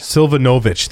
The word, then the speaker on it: Silvanovich: Was it Natalie Slinovich? How do you say her name? Silvanovich? Did I get that right? I apologize, Natalie. – Silvanovich. – Silvanovich. Thank you.